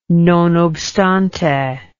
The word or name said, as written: non obstante